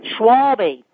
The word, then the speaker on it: Schwalbe: Schwalbe.